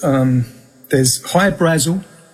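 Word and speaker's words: Hy-Brasil: There's High Brazil. High Brazil, people pronounce it High Brazil, and it's... – yeah, Brazil, okay. – Yeah, yeah. But it's High Brazil because that's how the Irish would pronounce it, because it's an, okay.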